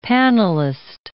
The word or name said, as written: panelist